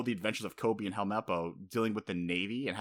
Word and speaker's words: Helmeppo: Kobe and Helmeppo. Sorry, Helmeppo.